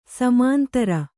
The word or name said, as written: ಸಮಾಂತರ